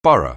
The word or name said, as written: Borneo